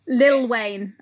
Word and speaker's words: Lil Wayne: Little Wayne. – Lil Wayne, I believe it's pronounced.